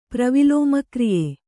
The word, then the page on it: ಪ್ರವಿಲೋಮಕ್ರಿಯೆ: Kannada